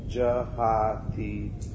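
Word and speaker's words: jahātīha: Now notice here, it's jahatiha. Short and then long A. Jahatiha. And that's a T. Not a tit, but a T. Jahatiha. Not ha ha.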